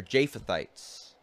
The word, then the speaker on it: Japhethites: it's going to be ruled by Japhethites, or Japhethites. I always pronounce it Yah. I just find it easier to say.